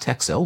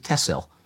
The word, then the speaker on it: Texel: And I'm going to correct you now and say it is not Texel, which is how it's written. – Yeah. – T-E-X-E-L. They pronounce it Tessel. – Okay. – And the locals will tell you it's because X means SS.